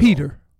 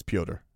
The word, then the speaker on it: Petr: Peter. – It's Pyotr. He's Russian. It's spelled P-T-R. – Peter. – You can say Peter, but that's not how it's said. – How is it said? – Pyotr. – P-E-T-R.